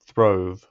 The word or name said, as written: throve